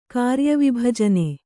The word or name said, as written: ಕಾರ್ಯವಿಭಜನೆ